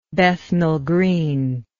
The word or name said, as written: Bethnal Green